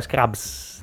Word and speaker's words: Scrubs: Questa è la sigla di Scrub, quindi con Lazo Ble- Bane. Lazlo. Vabbè, quel che è, come si dice? – Laszlo Blaine l'hai detto giusto, però è Scrubs.